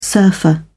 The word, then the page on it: surfer: British English